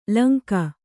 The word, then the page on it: ಲೈಂಗಿ: laingi